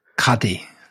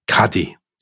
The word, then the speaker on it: qadi: And he had been officially named by the Sultan as a, I don't know how to say this, Tom, you'll have to explain. Is it a Qadi? A Qadi? Islamic judge? – Yeah. – Is it a Qadi? – Yeah. – You sure? – Yeah. – You're not bluffing? – Qadi. – Qadi.